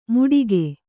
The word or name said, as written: ಮೂಡಿಕೆ